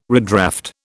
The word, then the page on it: redraft: British English